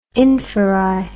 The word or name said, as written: inferi